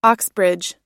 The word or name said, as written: oxbridge